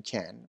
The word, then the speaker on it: can: زمانی که مثبت هست یعنی can، فشار یا استرس روی فعل هست، یعنی can خیلی خفیف تلفظ میشه و can تلفظ میشه. اما اگر منفی باشه استرس و یا فشار کلام روی can't هست و کاملا واضح و پرفشار تلفظ میشه.